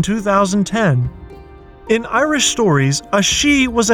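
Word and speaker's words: sídh: The word of the day is she, spelled S-I, often with an acute accent, D-H.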